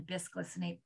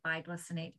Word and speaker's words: bisglycinate: Bisglycinate, biglycinate, depends on tomato, tomato. It's spelled B-I-S.